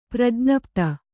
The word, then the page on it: ಪ್ರಜ್ಞಪ್ತ: prajñapta